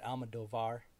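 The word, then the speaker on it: Almodóvar: It is written and directed by, you'll have to correct me if I pronounce this incorrectly, but Pedro Almodovar. – You got it pretty good. Almodovar.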